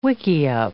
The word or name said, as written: wickiup